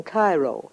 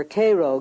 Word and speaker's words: Cairo: Oddly enough, though, Illinois is sometimes referred to as Egypt because the fertility of the soil in and around Cairo, or Cairo, giving it the Illinois pronunciation, resembles that of its Egyptian namesake.